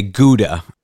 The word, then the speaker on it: Gouda: Or as we North Americans say, Gouda.